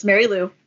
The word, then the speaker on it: Marielou: It's Marielou Mandl, like handle. – Mandl. Okay. – First name wrong. It's Marielou. You got Marielou. It looks like Marielou, but it's Marielou.